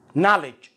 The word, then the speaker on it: knowledge: مثل كلمة knowledge knowledge